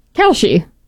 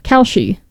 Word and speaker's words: Kalshi: Calchi. – It's like K-A-L-S-H-I. How else would you pronounce that? Calchi.